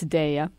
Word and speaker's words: Daya: I learned how to pronounce it correctly because everybody calls it Daya, but it's Daya.